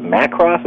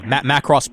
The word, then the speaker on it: Macross: Was it Macross? Am I pronouncing it right? – Yeah, Macross Plus.